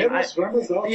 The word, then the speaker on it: Eros Ramazzotti: Eros Ramazzotti.